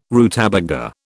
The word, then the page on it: rutabaga: British English